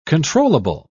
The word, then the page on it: controllable: British English